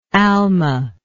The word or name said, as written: almah